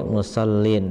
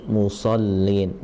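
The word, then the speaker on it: لِلْمُصَلِّينَ: Nah itu huruf sod, jadi naik ya, jangan sampai musallin, tapi musallin, musallin ya.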